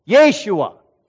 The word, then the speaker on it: Yeshua: His very name, Yeshua, that's right, that's how you say it, means Yahweh is salvation.